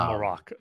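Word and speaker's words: Marrok: I think it's Marok. That's how I remember Baylan saying it.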